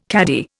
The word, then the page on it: caddie: Indian